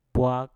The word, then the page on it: bwak: Mpur